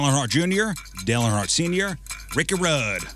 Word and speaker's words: Ricky Rudd: Hey, you didn't just say Ricky Rudd, you said Ricky Rudd. – Which is how you're supposed to say it, which is exactly how it's pronounced.